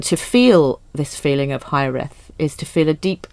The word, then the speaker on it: hiraeth: And the title that she eventually um, ended up with is a Welsh word, which is, I think this is how you pronounce it. It's uh, hi, hi, hiraeth, hiraeth, hiraeth, yeah, hiraeth.